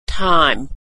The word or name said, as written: thyme